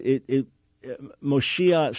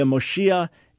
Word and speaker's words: Moshiach: So, yeah, Moshiach is not a play on Mashiach. Again, different vowels at the beginning, one with the long O, the Mo, the other with an A sound, and then a very different final letter.